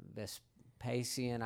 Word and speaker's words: Vespasian: Vespian um, uh, Vespasian, I forget how you pronounce uh, one of the Roman rulers.